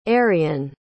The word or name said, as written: ariyn